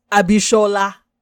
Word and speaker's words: Abishola: Bob Hart's Abishola. I had to say it right. For those of you who don't know, I'm actually Nigerian. So, I'm a stickler for saying things right.